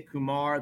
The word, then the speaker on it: Kumar: And I don't think he's cares too much which way it goes, but I say Kumar. That's how he told me it was said the first time I met him when I specifically asked, and that is the correct Indian origin ethnic pronunciation.